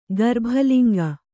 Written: ಗರ್ಭಲಿಂಗ

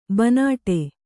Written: ಬೈನಾಡು

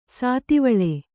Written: ಶಾದ್ವಲ

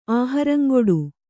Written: ಆಹರಂಗೊಡು

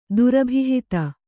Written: ದುರಭಿಹಿತ

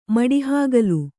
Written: ಮಡಹಾಗಲ

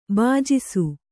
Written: ಭುಜಿಸು

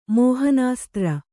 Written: ಮೋಹನಾಸ್ತ್ರ

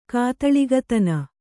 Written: ಕಾತಳಿಗತನ

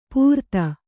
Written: ಪೂರ್ತ

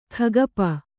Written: ಕೈಗೊಪ್ಪೆ

